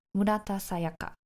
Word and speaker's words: Murata Sayaka: So, Murata Sayaka. That is how it's supposed to be pronounced in Japanese.